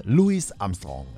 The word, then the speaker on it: Louis Armstrong: Wird englisch ausgesprochen mit S hinten aussprechen. Ne? Louis Armstrong.